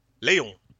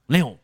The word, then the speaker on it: Léon: Ja, het is, het is, zijn naam is Frans. Er zit een streepje op de E precies, dan weet je dat het eh, niet anders uitgesproken kan worden dan uh, Leon. – Leon.